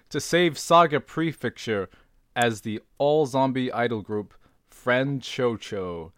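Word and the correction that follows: Franchouchou: I have pronounced literally everything wrong in this whole summary. – It's Franchushu. – Franchushu.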